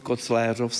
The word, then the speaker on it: Kocvířov: A v současné době se ustálila tedy výslovnost Kocvířov s E.